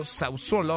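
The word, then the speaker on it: Sassuolo: El futbolista Caputo de, un equipo llamado Sausolo, ahora me corregirá Nicolás Romay si es que lo estoy pronunciando bien, celebró su gol en un partido a puerta cerrada para mostrar una cartulina con el mensaje Todo estará bien, quédate en casa. – Deportes con Nicolás Romay. – Querido Nico, qué gusto saludarte, ¿cómo estás? – Bien, Manuel, me da gusto saludarte. Una pronunciación extraordinaria.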